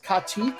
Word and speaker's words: katyk: Now, a real Uzbek cold shalop, this is a soup, by the way, is cooked on a mixture of something called, I believe it's going to be pronounced katyk, K-A-T-Y-K, and cold water. Katyk, by the way, is a sour milk, and it's fermented with a grainy thing called Bulgarian bacillus.